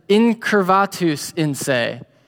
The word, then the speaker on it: incurvatus in se: I just looked up a YouTube video on how to pronounce it yesterday. But it's incurvatus in se.